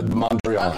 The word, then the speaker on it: Mondrian: Oh, remember it was supposed to be the I don't know how you say it, the Mondrian? – The Mondrian.